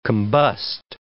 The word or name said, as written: combust